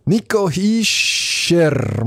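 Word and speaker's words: Nico Hischier: Nico Hischer, muss man den mal ja korrekterweise aussprechen.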